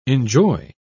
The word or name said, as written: enjoy